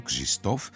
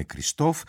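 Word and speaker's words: Krzysztof: Ο Κριστόφ Εουγένιος Πεντερέτσκι, έτσι προφέρεται στα πολωνικά το όνομά του, Κριστόφ, παρόλα αυτά ας μας επιτραπεί να τον αποκαλούμε Κριστόφ, γεννήθηκε στις 23 Νοεμβρίου του 1933 στην πόλη Ντέμπιτσα.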